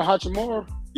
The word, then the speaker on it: Hachimura: I can't pronounce the last name. Who Chiroar? Chiroar? – Roar Hachimura? – Yeah, I like him.